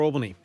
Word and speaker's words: Albany: Albany or Albany? Albany. – Albany. – They pronounce it Albany.